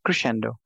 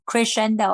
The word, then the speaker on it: crescendo: How do you pronounce that? – Crescendo. – Crescendo.